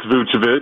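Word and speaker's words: Vučević: You pronounced it correctly. It's Vucevic.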